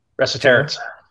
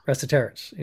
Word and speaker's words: Resetarits: And then you got guys like Tom Schreiber, Joe, I can never say his name properly, Resetarits. – Resetarits. – Resetarits.